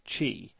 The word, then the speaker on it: qi: But of course, the whole point of this is to prove the existence of qi, or qi, however you pronounce it.